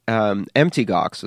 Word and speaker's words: Mt. Gox: Well, the thing is, Mt. Gox, as I like to call it, which kind of rhymes with empty Gox, because it really is just an acronym, it's not a mountain.